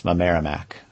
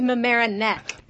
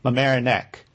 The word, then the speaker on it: Mamaroneck: Well, actually, I'm going to correct you. It's actually Mamaroneck. – Mamaroneck. – Yes, you got it.